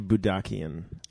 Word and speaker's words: Boodakian: My real last name is actually Boodakian.